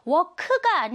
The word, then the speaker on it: work: work가 아니고요. work 끝에 살짝 끄 발음이 나는 거. work, work. 좋아요.